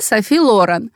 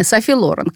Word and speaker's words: Софи Лорен: Значит, идеалом вообще хождения на каблуках много-много-много десятилетий подряд была знаменитая итальянская кинозвезда Софи Лорен. Именно на первый слог ударения. Софи Лорен, которую ее тогда возлюбленный, а дальше муж Карло Понти, который, собственно, и сделал из нее звезду, учил ходить.